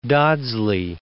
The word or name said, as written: Dodsley